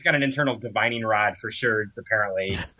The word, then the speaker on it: sherds: sherds rhymes with birds.